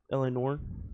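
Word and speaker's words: L.A. Noire: L.A. and Noir. Let me read that again. I pronounced it really weird. – L.A. Noir? – L.A.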